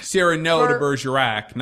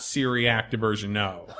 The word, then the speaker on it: Cyrano de Bergerac: Cyrano de Bergerac, not Syriac de Bergerac.